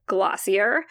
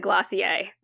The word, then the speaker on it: Glossier: I'm basically Gen X, but where I have leaned into being a millennial is a brand called Glossier. Now, I know they pronounce it Glossier, but I refuse to pronounce it like that because it's not actually a French word.